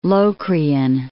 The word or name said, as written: Locrian